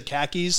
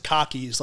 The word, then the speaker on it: khakis: So I mean, typically I say khakis, so I don't really fuck it up too much. But a lot of people in Boston say pronounce it cockies, like the pants.